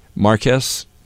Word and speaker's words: Marquess: Is this pronounced Marquess? – Marquess.